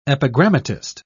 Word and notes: epigrammatist: British English

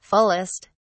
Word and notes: fullest: British English

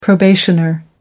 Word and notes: probationer: American English

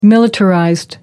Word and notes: militarized: American English

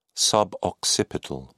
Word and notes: suboccipital: British English

